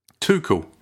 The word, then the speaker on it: Tuchel: How do you pronounce our manager's name? – Tuchel. – Tuchel is absolutely correct.